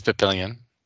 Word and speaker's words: Papillion: I bet it's not pronounced Papillion. – It is Papillion.